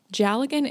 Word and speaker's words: Dealgan: Jallagan is spelled D-E-A-L-G-A-N, and I will provide some links.